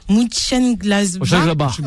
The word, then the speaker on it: Mönchengladbach: Donc ça joue entre justement Dortmund deuxième, Leipzig qui est repassé devant, alors attention pour la prononciation, Mönchengladbach.